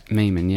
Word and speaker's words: Maiman: By Dr. Theodore, I can't remember how to pronounce his name. Dr. Theodore Maiman. – Maiman, yeah. So what happened was,